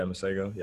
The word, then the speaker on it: Masego: I like uh, I like the guy Masego. Is that how you say it? Yeah, Masego.